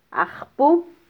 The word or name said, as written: ajpu